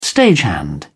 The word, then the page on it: stagehand: British English